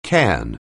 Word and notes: cairn: anglais

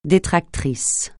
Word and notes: détractrice: francais

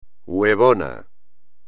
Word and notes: huevona: espagnol

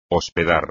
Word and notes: hospedar: espagnol

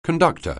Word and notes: conductor: anglais

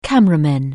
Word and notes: cameraman: anglais

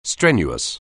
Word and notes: strenuous: anglais

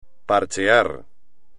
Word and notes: parchear: espagnol

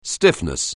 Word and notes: stiffness: anglais

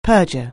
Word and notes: perjure: anglais

